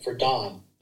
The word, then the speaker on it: Dohn: And, you know, the biggest thing is my name is spelled D-O-H-N. And that really is an unusual spelling for Dohn, right? – Right. – So, yeah, Dohn Thornton, T-H-O-R-N-T-O-N.